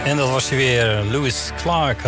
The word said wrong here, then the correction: Louis Clark: Louis Clark moet ik zeggen, want dat is de juiste uitspraak.